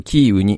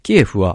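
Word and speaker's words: キ ー ウ (Київ): で、政府は 31 日、ウクライナの首都キエフのえー、名称表記について、ウクライナ語の発音に基づくキーウに変更すると発表した。キエフはロシア語の発音に由来し、自民党などからキーウへの変更を求める声が出ていた。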